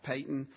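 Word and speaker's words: Paton: I know it looks like Peyton. That's how it's pronounced. I checked multiple times.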